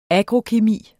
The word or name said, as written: agrokemi